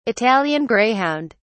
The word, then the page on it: italian greyhound: British English